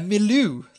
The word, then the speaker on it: milieu: A milieu.